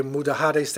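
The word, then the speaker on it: Mudéjares: And the Spanish, they say Mudahares, they don't pronounce the J.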